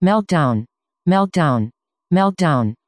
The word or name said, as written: meltdown